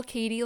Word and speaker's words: Katy: So my social handles, my Instagram, my personal one here is Katie Spudo. And let me spell that, especially for people who don't know me. So I spell Katie like you would spell Katy Perry. So K-A-T-Y.